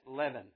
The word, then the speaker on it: leaven: Finally, before the presentation, I had one of the guys come and say, hey, preacher, that's leaven, like heaven, leaven. It's spelled L-E-A-V-E-N.